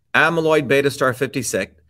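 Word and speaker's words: amyloid beta star 56 (Aβ*56): The UMN team discovered a previously unknown oligomer dubbed A-beta 56, pronounced amyloid beta star 56, after its relatively heavy molecular weight compared with other oligomers.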